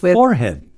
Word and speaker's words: forehead: Horrid rhymes with forehead.